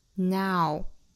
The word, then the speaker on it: now: 最后的这个 now 虽然是非常小非常简单的一个词，但是一定要注意双元音的滑动感要出来。 Now, now, 嘴型张大 ,ow, 不要读成了 no.